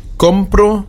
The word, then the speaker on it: compro: Bitte achten Sie auch auf die Betonung. Wir haben compro, compras. Compra, auf dem O betont. Compramos, comprais, auf dem A. Und compran, wieder auf dem O.